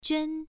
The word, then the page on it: juan: 1